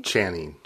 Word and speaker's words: Channing: Channing.